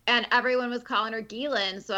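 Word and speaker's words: Ghislaine: He called her Jizz Lane.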